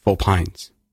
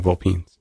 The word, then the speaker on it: Vulpynes: Because you said Vulpynes. – Yeah. – And I've been saying Vulpynes.